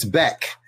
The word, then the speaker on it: Beck: And his name, it rhymes with Zek. It's Beck.